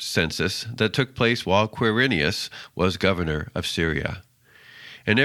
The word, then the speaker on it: Quirinius: And by the way, it is pronounced Quirinius.